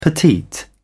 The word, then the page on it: petite: British English